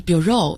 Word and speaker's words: bureau: 那么例如我们经常说这是我们局办公室，我们会说 This is the office of our bureau。那么这个词来自于法语，也有人读成 bureau，所以大家注意一下重音的位置是不同的。